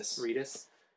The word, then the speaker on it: Reedus: It has Norman Reedus. How do you say his name? – Reedus.